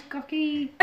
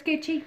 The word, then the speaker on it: Gucci: If you've ever seen that episode of Friends, episode of Friends, where she's like, it looks like it should be pronounced gucky. But it's Gucci.